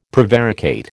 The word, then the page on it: prevaricate: American English